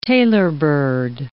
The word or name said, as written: tailorbird